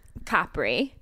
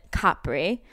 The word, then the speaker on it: Capri: So I'm in Capri. That's how you pronounce it. Capri.